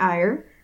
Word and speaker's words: Eyre: I will be playing Radiant Ire. That is E-Y-R-E. And it's pronounced like the emotion.